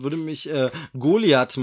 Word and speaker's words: Goliath: Richtig, und ich glaube, man darf es Goliath aussprechen. Ähm, ich habe irgendwo einen Werbespot gehört und da haben sie es äh, so ausgesprochen. Also wir blamieren uns nicht, wenn wir das TH umgehen heute mal.